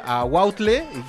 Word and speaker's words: aguautle: la cual nos va a hablar sobre el aguautle y primero que nos diga si lo estamos pronunciando bien. Sí, sí, sí.